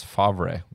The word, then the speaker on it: Favre: His name is Favre, but it's pronounced Favre.